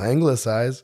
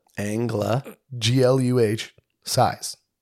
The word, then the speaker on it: anglicize: I found the pronunciation of anglicize. – Oh, what is it? – Angluh. Size. – Oh. – Anglicize.